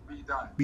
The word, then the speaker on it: B Dot: They think it's Asian or some foolish like that, but it's pronounced like DOT. It's B dot.